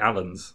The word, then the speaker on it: Alan: And it's spelt like A-L-A-N. – It's Alan, yeah.